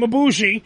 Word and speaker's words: Ibushi: Because the main event of the Cruiserweight Classic was supposed to be Ibushi, which, by the way, I'm being told is how you say his name, Ibushi, and Zack Sabre Jr. Because that would have been the legendary, amazing fucking match.